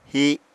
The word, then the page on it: hi: Apatani